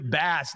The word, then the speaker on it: Bass: Under the pronunciation guide, Tyler Bass, next to Bass, they write in parentheses, like the fish.